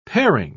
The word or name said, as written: paring